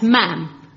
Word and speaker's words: ma'am: And the first one is that Princess Anne must be addressed not as Mom, but as Ma'am. Brackets, yeah. Rhymes with ham.